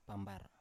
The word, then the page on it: pambar: Biak